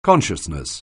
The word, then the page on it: consciousness: anglais